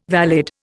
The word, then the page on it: valid: Indian